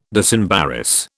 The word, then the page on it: disembarrass: British English